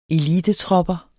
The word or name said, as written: elitetropper